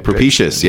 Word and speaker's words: propitious: Propitious. – Ooh. – Mm-hmm. That's right. You said it right. Propitious. Yeah. Yeah, or propitious.